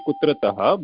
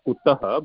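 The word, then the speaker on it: kutaḥ: But it's not actually Kutrataha, but it is Kutaha, but you can think of it like from where.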